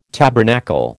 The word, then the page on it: tabernacle: American English